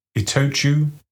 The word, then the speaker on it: Itochu: Okay, so it's Mitsui, Itochu, Marubeni and Sumitomo.